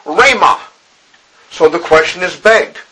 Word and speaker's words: rhema: it is the Greek word rhema. And it's spelled R-H-E-M-A, rhema.